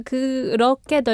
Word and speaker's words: 그렇게: And I also found the 그렇게, the lear pronunciation is like 그렇게, 그렇게. – 그렇게. – Yeah. – 그렇게. But the lear is not the same as an L, right? – So 그렇게.